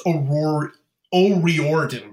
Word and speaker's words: O'Riordan: It's O'Riordan, by the way. I totally mispronounced that before, which is sad.